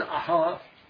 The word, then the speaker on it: Ahasuerus: Now it came to pass in the days of Ahasuerus, sometimes pronounced Ahasuerus, that is, Ahasuerus, which reigned from India even unto Ethiopia, over 127 provinces.